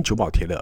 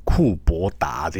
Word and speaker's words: 久保田: 同样的啊，有名的酒久保田，我们就直接发音久保田了，我们不会叫他什么库伯达这种库伯达。